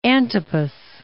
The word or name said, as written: Herod Antipas